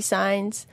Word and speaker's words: Saenz: Facebook, Instagram, Twitter, all the same username, Desi Signs. – Awesome. – So yes. – And Signs is spelt for everybody? – S-A-E-N-Z. – Awesome. Cool. Not like the signs. – Yes, stop signs.